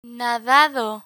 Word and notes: nadado: Spanish

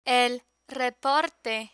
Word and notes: el reporte: Spanish

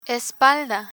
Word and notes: espalda: Spanish

